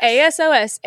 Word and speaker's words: ASOS: But A S O S A S O S ASOS. ASOS, I think is how you pronounce it.